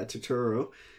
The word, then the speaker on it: Turturro: Turturro? Okay, yeah, Turturro.